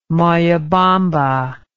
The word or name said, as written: Moyobamba